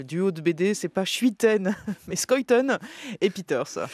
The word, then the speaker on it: Schuiten: Et français, le dessinateur François Schuiten, oui, on dit Schuiten, est belge.